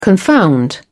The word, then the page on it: confound: British English